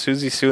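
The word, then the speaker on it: Siouxsie Sioux: I think it's pronounced Susie Sue in the Banshees version.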